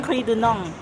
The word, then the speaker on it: Henry Dunant: Yang itu apa namanya Bapak Henry Dunant dulu dirikan. – Oh baiklah Henry Dunant. – Ongri Dunong. – Ongri Dunong. – Oh iya salah ya bacanya.